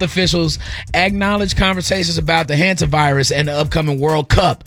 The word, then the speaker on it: Hantavirus: What's the pronunciation? – You said it right. – Hantavirus? – Hanta.